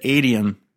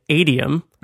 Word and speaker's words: Adium: So this is a little point of contention for this very popular Mac messaging application. And so the name is spelled A-D-I-U-M. And if you're an English speaker, there are definitely more than one way that you could pronounce that.